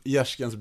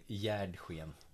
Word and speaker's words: Gärsken: Modeklubb, Gärskens BK. – Ja. Det är så man uttalar det. Gärsken. Jag hade ju fått det till Gärdsken.